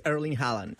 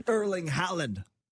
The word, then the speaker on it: Erling Haaland: How do I say it again? I'm terrible with names. – No, this guy is Erling Haaland. – Erling Haaland.